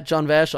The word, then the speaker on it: Jon Vash: Oh, at Jon Vash on Instagram. – Spell it. – That's J-O-N-V-A-S-H.